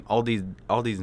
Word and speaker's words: Aldi: I went to the Aldi's. – Okay, I believe it's pronounced Aldi.